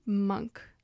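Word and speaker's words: Münch: And Morton Munch. – Morton. I think it's actually Monk. – Oh, that's my bad. There's a little accent on the U.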